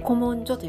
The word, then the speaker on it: 古文書: て担当してきましたこの試験というのが古文書という古文書検定という試験だったんですけれども古文書とどう書くかっていうと古い文古文ですね古文に書くという字で古文書と読む